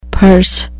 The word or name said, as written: perse